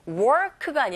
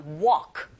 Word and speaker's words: walk: 제가 두 번이나 실수했는데 work가 아니라 walk. 걷다죠. 그죠? walk는 L이 묵음이고 일하다 work는 R소리가 확실히 들릴 수 있도록 네, 제가 잘못 발음한 work. 이렇게 발음하셔야 됩니다. 여기선 walk가 맞죠.